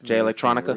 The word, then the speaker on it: Jay Electronica: Jay Electrica. – Oh, Jay Electronica? What did I say? Jay Electrica? – Yeah, this Jay Electronica.